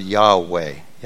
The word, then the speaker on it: Yahweh: The best pronunciation we can get close to is the word Yahweh.